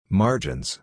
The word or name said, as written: margins